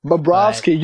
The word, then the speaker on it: Bobrovsky: And I think it just starts off with their goaltending from Sergei Bobrovsky. I can't pronounce his name, man. – Bobrovsky.